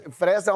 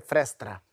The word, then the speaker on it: fresta: Fresta, parece que é frestra. Fresta. – E eu falei meio se eu for cuidando. Carioca mesmo, é fresta. – Fresta. Isso. – Fresta, quase festa, mas é fresta.